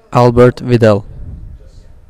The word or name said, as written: Albert Widell